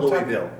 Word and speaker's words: Louisville: It's called Louisville. – Oh, but it's with an S, with a right? – Yeah, yeah. They don't pronounce it. French.